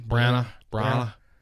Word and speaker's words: Branagh: Kenneth Branagh.